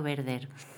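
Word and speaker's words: Werther: Bueno, creo que por mi, pronunciación alemana sería el efecto Werder.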